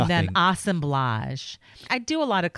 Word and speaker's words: assemblage: Assemblage. – No. The way the art snobs say. – Oh, assemblage. – Assemblage. – Yeah, think of collage.